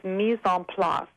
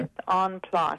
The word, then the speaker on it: mise en place: Now, I wanted to talk about, you talked about the importance of a well-maintained pantry, and you used a French expression, mets et pas. I speak more Spanish. I don't speak French. – I will give you the French pronunciation. – Oh, please do. – It's mise en place.